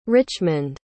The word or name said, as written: Richmond